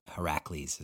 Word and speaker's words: Heracles: And he said his game really started to flower when he moved to the number 8 for Heracles, is apparently how you say it.